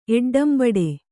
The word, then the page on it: ಎಡ್ಡಂಬಡೆ: Kannada